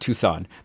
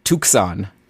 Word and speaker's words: Tucson: I think it's pronounced Tucson, but uh, regardless. Okay, so let's go down to St. Louis. – The what final. Tucson. Tucson. – Tucson. – I think the C is. I think the Tucson. C is silent. – It's like a soft C.